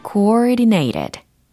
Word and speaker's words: coordinated: coordinated coordinated. 천천히 발음을 해드렸어요. coordinated. coordinated 이게 아니고 coordinated. coordinated. coordinated. coordinated. 이렇게 연습하시면 됩니다. C-O-O-R-D-I-N-A-T-E-D 라는 철자이고요.